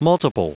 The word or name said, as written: multiple